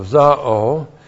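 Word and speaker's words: zao (ζάω): And then we have the verb zao. Z-A long O, zao.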